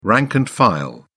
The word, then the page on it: the rank and file: British English